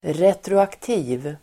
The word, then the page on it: retroaktiv: [retroakt'i:v (el. r'et:ro-)]